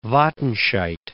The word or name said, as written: Wattenscheid